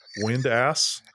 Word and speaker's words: Windass: I think Windass is one word, and I'm positive that I'm pronouncing it correctly. Windass. – You are, yes. It is. It is.